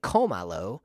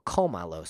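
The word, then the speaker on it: cómalo: But if I say komalo, that word's stressed on the third to the last syllable, komalo, so I have to write in an accent.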